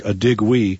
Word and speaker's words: Adigwe: Pastor Peter Adigwe. – Okay. – It's spelled A-D-I-G-W-E. It looks like Adigwe, but it's pronounced Adigwe.